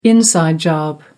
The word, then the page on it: inside job: American English